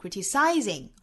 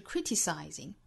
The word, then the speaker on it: criticizing: Whenever you feel like criticizing anyone, 这个词不要读成 criticizing, 而是 criticizing. Criticize. Criticize. 重音呢是第一个音节，我们掌握节奏应该是哒哒哒哒哒哒。